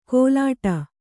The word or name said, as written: ಗೋಲಾಟ